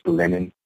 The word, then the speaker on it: Lennyn: It's spelled L-E-N-N-Y-N. How do you pronounce that? Yeah, it's pronounced Lennon. And so it's it's after John Lennon.